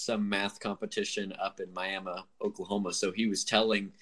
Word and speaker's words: Miami: And the funny thing is that everyone that's not from Oklahoma pronounces it Miami. – Yeah. – And I'm like, no, it's Miami.